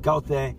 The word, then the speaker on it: Goethe: In Germany, somebody like Goethe, that's how you pronounce him, very good chance people don't know, right?